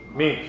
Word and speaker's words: memes: I wonder if, you know, there's this little, what they call them, memes. I always say that wrong. Memes, meme? Memes, thank you.